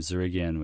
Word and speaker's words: Dzerigian: Or you can just click on the search engine in their website and type in my last name, Zarigian, which is spelled D-Z-E-R-I-G-I-A-N.